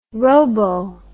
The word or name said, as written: robo